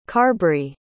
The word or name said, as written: carbry